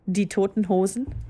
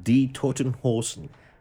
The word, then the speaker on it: Die Toten Hosen: Say it again. – Die Toten Hosen. – Die Toten Hosen. I said it right? – Yes. No, that was that absolutely correct.